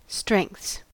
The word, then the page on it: strengths: American English